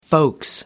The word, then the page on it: folks: British English